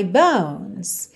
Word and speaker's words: bones: Atenção para essas letrinhas E pintadas de cinza que não são pronunciadas, ok? Inside my bones. Bones. Do N você já vai pro S.